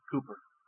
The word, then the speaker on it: Cowper: It's spelled to be pronounced Cowper by our reckoning, but the English pronounced it Cooper.